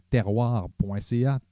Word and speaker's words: Terroir: Je vous invite évidemment à aller visiter notre page Facebook, notre Instagram, mais aussi notre site internet terroir.ca, T-E-R-W-A-R.ca. Terroir, ça se prononce en fait comme ça, c'est la terminologie qu'on a utilisée, T-E-R-W-A-R.ca.